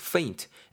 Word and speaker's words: faint: faint, F 사운드 발음 잘 하셔야 되고요. F 사운드 페인트 아닙니다. faint, F 사운드 살려야 되고 실신하다입니다, 여러분.